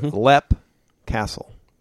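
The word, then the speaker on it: Leap Castle: It is Lep Castle. – That's right. – Da, da, da. Right? – Lep Castle. – I even said it right. I didn't say Leap. – I know.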